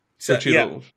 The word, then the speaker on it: Xochitl: Because I want to say Xochitl, because I know in Portuguese, the X is a ch sound, but I know that it's not, like, it's a Mayan derivation, so it changes.